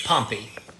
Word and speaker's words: Pompey: Pom- Pompey not Pompeii.